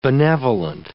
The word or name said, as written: benevolent